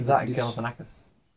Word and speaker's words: Galifianakis: Zach Galifianakis.